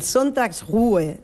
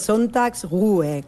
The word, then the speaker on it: Sonntagsruhe: A ver, el Sonntagsruhe, es una palabra difícil de pronunciar aún así, es Sonntagsruhe, que al final es descanso dominical, básicamente.